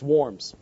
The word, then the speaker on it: Worms: And the Diet actually is Parliament, and it is to be pronounced Worms.